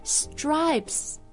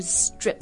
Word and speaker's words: stripes: 所以 stripes, S-T-R-I-P-E-S, 它的发音是 stripes, 不是 strips.